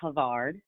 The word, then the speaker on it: Havard: So I pronounce it Havard. – Havard, that makes way more sense. Yes. – That's okay. I get everything. I get Howard. I get Harvard.